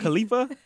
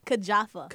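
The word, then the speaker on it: Kijafa: Was that Khalifa? That's a Khalifa, right? – No. – It's not Khalifa? – Kajafa. – Kajafa? Okay, all right. Well, we're saying it wrong.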